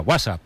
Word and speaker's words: WhatsApp: No, peor aún, con acento en la primera. WhatsApp, ¿no?